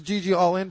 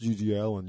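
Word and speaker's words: GG Allin: This is GG All In podcast. It's like, it's pronounced GG Allen, you dummy.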